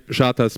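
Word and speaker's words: ŝatas: es gibt so ein paar Buchstaben mit Dach drüber, wie hier dieses S, und das wird einfach ausgesprochen wie ein deutsches SCH, genau, und mi shatas pizzon, und dann unten als Beispiel eben mit einem Vergleich, mi shatas pizzoin pli ol ananasoin.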